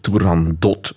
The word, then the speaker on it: Turandot: Hoe spreek je eigenlijk die naam uit? Is dat... Want het is eigenlijk ah. Perzisch. en het zou dus eigenlijk Turandot moeten zijn. Oei.